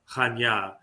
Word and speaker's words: Chania: And Chania, spelled in English, is Chania, Chania, but it's pronounced Chania.